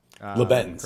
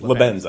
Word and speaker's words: Lebanz: Am I pronouncing that? – Lebanz. Correctly. Excuse me. Lebanz, I say.